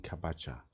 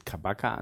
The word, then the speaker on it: Kabaca: Ähm, das äh, C im, im Türkischen äh, wird ja eigentlich mit einem äh, äh, unten gibt es noch einen Strich unterm C, äh, da gibt es kein normales C und deswegen wird das Sch ausgesprochen und deswegen Kabacha.